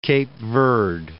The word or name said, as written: Cape Verde